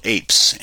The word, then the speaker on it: AIPS: But one of the cool things, if anybody wants to see how is open source is being used, there's a program called APES, and it's spelled AIPS.